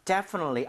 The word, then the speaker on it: definitely: 자, definitely. 앞쪽에 이거를 청취로 알아들으려면 강세를 알아야겠죠? 바로 예. – 여기에 넣는 거예요. 그래서, 틀리, 이렇게 안 되게 빠르게 가야 되는 거죠. 약간, definitely. Right? 자, 해보겠습니다. Definitely. Definitely.